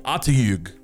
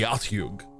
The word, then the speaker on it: Otyugh: It's called the Atyug. O-T-Y-U-G-H. The Atyug.